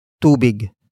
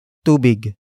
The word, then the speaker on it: tubig: And that is tubig. Tubig.